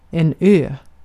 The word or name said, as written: ö